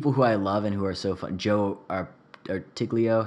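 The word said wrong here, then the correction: Truglio: how do you say his name? – Yeah. – Yeah. The guy from Brooklyn Nine-Nine. – Trulio. Joe Trulio. That's it. – Trulio. Right.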